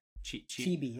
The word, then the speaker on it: chibi: Chibi, yeah.